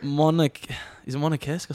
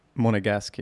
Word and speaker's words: Monegasque: Is it Monacesque or something? – Wrong. Right. Ale. – Monegasque, yeah? – Monegasque.